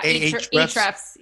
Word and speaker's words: Ahrefs: Ahrefs? Ahrefs? That's one of my favorite. – Yeah, Ahrefs.